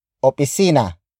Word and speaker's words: opisina: Opisina is spelled as O-P-I-S-I-N-A.